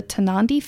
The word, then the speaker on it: Tarnanthi: I wanted to talk about the Tanandi Festival, and I hope I'm saying that correctly.